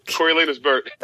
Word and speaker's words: Coriolanus Burt: I'm trying to remember how to pronounce it. Coriolanus Burt, because I love me some Coriolanus Burt. – That's the one.